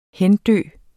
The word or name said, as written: hendø